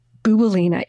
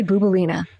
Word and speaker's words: Bouboulina: Laskarina? Bouboulina. It rhymes. I say Laskarina, you say Bouboulina. I say Laskarina, you say... – Bouboulina.